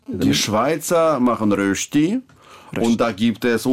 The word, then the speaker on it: Rösti: Rösti sein, ja. – Also, Sie können das mit den Rösti gut aussprechen. – Rösti. Rösti. Ja, Na, ich habe ja sehr viel davon gegessen.